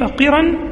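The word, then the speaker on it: قِرى: وأصل تقري الضيف من قريت الضيف قرا، وقراء من قريت الضيف قرا وقراء، وقرا بكسر القاف، وقراء بالقصر بالمد بفتح القاف والمد، قراء يعني تنطق قرا بكسر القاف والقصر، وقراء بفتح القاف والمد، تقول قريت الضيف وأقريه.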